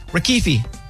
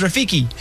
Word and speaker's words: Rafiki: Rafiki. Familiar? – Never heard of him. – What kind of animal is Rafiki? Yeah, I said it wrong. Yeah, Rafiki. Yeah, that's why I said it again.